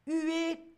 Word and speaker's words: huer: Huer, toujours avec ce H qu'on ne prononce pas en français, huer.